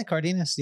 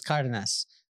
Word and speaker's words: Cardenas: Is it Cardenas? – Cardenas. Yeah. My grandma would be mad at you. Why? But she always said that it was Cardenas, which it is.